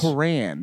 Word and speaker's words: Quran: The Quran. Make sure you pronounce it that way, by the way, too.